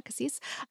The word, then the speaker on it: Cassis: Yeah, Cassis.